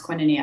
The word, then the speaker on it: Koinonia: And then when we moved to Americus, Georgia, where I went to high school, when we moved away from Koinonia, it's pronounced Koinonia, actually. It's a funky, weird name. It's a Greek word that means community.